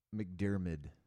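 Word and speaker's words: McDiarmid: McDermott. – McDermott? – I think it's McDermott. – McDermott. McDermottitis. – McDermid. I was saying it wrong. – Is it Ewan? Or Ian? – No, it's actually Ian. I-A-N.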